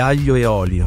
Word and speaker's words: Aglio e Olio: Ich weiß inzwischen, da spricht man so ein bisschen sanfter aus. Moment, ich habe mal den Übersetzer hier gefragt. – Spaghetti Aglio e Olio.